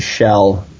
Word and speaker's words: Kjell: His name is Shell, so it's spelled K-J-E-L-L.